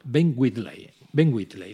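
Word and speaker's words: Ben Wheatley: que se llama Ben Whitley, lo he dicho bien, ¿verdad? – Sí, lo has hecho perfecto.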